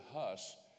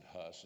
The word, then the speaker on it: Hus: And when they were going to burn him to the stake, he said to them, and the word Huss in Eastern Europe is pronounced Hus. We pronounce it Huss in the English. It means goose.